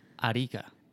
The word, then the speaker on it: Arica: Well, you pronounce it the Erica Desert, right? – Yeah, Arica. – You guys are too fast with the way you say it. – Arica? – Yeah. – But they say the same Arica. – About English. – Arica. – That's the R. The R is pronounced fast.